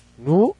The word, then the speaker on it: GNU: GNU Linux. Porque dice que... no, hasta nos dio la clase de cómo lo íbamos a pronunciar. GNU Linux.